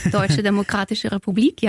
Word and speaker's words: Deutsche Demokratische Republik: Deutsche Demokratische Republik, ja.